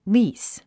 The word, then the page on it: lease: líːs; リース